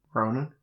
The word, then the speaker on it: Ronan: Johnny Lowen, is it? What? I can't get, I never get his name right. – Ronan. – Ronan, Johnny Ronan.